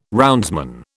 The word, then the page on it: roundsman: British English